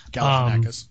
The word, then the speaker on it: Galifianakis: And it's, of course, it's star-studded with Julianne Moore, Vinnie Jones, Henry Rollins, Alan Tudyk, Alexis Bledel, Zach Galifianakis, whatever. – Galifianakis.